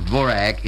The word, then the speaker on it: Dvořák: One cannot listen very long on the radio without hearing the compositions of Antonín Dvořák, who is pronounced Dvorak in America, and those of Rudolf Frimov.